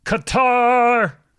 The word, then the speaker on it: Qatar: I actually prefer pronouncing it cutter, but people get tense when you do that. – Isn't that how it's actually pronounced, though? – You can pronounce it either way. Like, legitimately, it is pronounced either way.